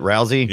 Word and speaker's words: Rousey: I mean, Rousey. How do you say it? Rousey.